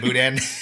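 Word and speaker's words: boudin: Is it Budan? Budan? Budan? – Budan. – Budan. Budan. That fucking sausage made of rice.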